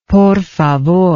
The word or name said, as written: por favor